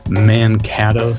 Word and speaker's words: Mankato: He teaches at the University of Minnesota Morris and is the principal percussionist of the Mankato Symphony Orchestra.